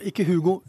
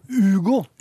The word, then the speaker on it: Hugo: Uten H, ikke Hugo. Hugo.